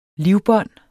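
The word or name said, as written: livbånd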